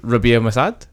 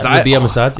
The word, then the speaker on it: Rabia Massad: And I was like, oh my God, you're Rabia Massad. That's how you say his name. I butchered it, haven't I? – No, that's it. Rabia Massad.